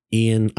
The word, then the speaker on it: Ian: And you're like, Ian, I-A-N, Boyce, B-O-Y-Z-E, because they can never spell that because it's hard to do.